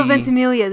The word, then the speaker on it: Ventimiglia: I mean, you get to see Milo Ventimiglia. – No, say his name right. – Ventimiglia? – Milo Ventimiglia. The G is silent.